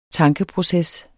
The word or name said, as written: tankeproces